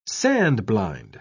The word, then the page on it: sand-blind: British English